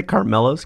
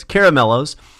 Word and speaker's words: Caramellos: Nicole says Caramellos. Did I say it wrong? Did I say Carmellos? Caramellos.